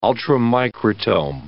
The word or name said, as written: ultramicrotome